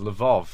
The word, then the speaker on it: Lvov: But I know Lviv as Lvov. There's an issue with Ukrainian versus Russian pronunciation, which is yet to be resolved after the breakup of the Soviet Union, funnily enough.